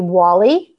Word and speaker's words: Wali: Today I have with me the fabulous Ashley Wali.